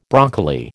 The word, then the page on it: broccoli: American English